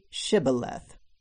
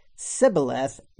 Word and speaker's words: Shibboleth: When he said, "No," they said to him, "Please say Shibboleth." And if he said Sibboleth, because he could not pronounce it correctly, they grabbed him and executed him at the fords of Jordan.